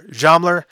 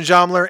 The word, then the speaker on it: Zschomler: Once again, still don't know what's going on at the celebration, but we do know that both Christian Zollmer, I can never pronounce that last name, her last name, Z-S-C-H-O-M-L-E-R, Zollmer, Christian Zollmer, and Charles Chaz Smith will be speaking at the Pollad Auditorium at the Hennepin County Library.